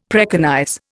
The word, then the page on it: preconize: Indian